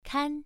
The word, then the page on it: kan: 1